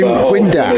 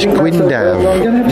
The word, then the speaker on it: Gwyndaf: It's not Gwyndaf. A single F in Welsh is pronounced like a V. A double F is pronounced as an F. A single X is pronounced like a V if you're English. So his name is is Gwyndaf, not Gwyndaf.